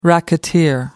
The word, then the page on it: racketeer: British English